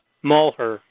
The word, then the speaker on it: Malheur: Mulher.